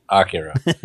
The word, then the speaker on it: Akira: Well, first of all, it's pronounced Akira.